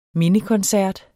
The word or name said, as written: mindekoncert